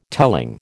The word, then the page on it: telling: American English